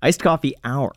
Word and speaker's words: Iced Coffee Hour: Ice Coffee House. And did I say that right? – Iced coffee hour.